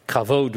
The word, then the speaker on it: kavod: The first word, kavod, would be the phonetic pronunciation.